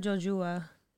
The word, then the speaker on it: Jojua: Courtney Casey versus Liana Jojua. – Okay. – Did I say it wrong? – No.